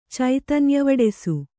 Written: ಚೈತನ್ಯವಡೆಸು

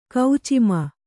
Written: ಕೌಚಿಮ

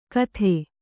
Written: ಕತ್ತೆ

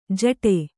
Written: ಜಟೆ

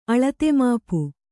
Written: ಅಳತೆಮಾಪು

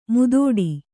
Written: ಮುದುಡು

